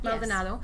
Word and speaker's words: Maldonado: which is on the coast, so Maldonado, am I pronouncing it right? Maldonado, yes.